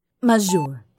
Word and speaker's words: majeure: This word here, how do you say that? Majeure. Majeure? – Majeure, dear.